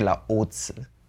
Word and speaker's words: Laozi: In German, we say Laozi. – Okay, that's actually pretty accurate pronunciation-wise.